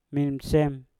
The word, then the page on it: minsem: Mpur